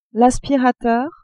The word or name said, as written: aspirateur